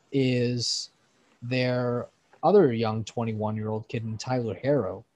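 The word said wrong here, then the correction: Herro: apparently being Hero. – Sorry. I pronounced the two R's instead of the, like, if there was another O there.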